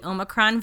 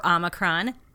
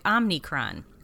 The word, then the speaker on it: Omicron: I am going to interview Dr. Caitlin Jedalina, who is also known as your local epidemiologist, about the Omicron variant, or Omicron. I don't care how you say it, as long as you don't say it Omicron.